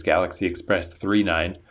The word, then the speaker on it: Galaxy Express 999: It features statues from Galaxy Express 999, which I think I read they pronounce it as Galaxy Express 39, and that symbolizes the railways, even though in this case it's railways in outer space.